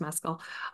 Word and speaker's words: Mescal: Sorry, I just learned his last name is pronounced Mescal.